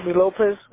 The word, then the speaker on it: Lopez: J-A-S-O-N, and then your last name. If you could just spell that for me. – Lopez, L-O-P-E-Z.